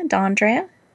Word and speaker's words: D'Andrea: D'Andrea? – D'Andrea.